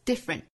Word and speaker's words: different: 后面 just different 后面的 t 略掉， different 第一个音节的这个 d 发音我们要爆破十足哈， different 要冲出来，这种感觉不像我们汉语之中的这个阿波茨的这个的的发音，英语呢是的是爆破感十足的。